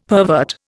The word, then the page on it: pervert: Indian